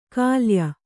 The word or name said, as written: ಖಲ್ಯೆ